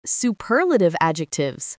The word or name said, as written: superlative adjectives